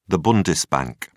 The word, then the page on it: bundesbank: British English